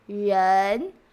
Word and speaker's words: rén: For example, the sound rén, rén. second tone. This syllable, this sound represent one Chinese character, which means people, or person. So when you see the pinyin, it is R E N and the second tone tone line.